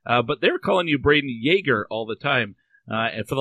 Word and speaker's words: Jagr: It doesn't really matter, but it is pronounced Yager.